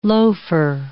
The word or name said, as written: loafer